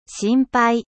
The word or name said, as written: 心配